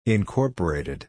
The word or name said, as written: incorporated